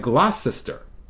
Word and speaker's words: Gloucester: Why is she pronouncing it like that when it looks like Gloucester?